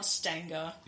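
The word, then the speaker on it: St. Anger: It's pronounced Stanger.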